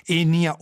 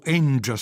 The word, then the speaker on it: Enja: Enia, o Enja se vogliamo pronunciarlo alla jazzistica, intitolata Dialogues, dialoghi.